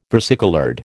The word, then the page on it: versicoloured: American English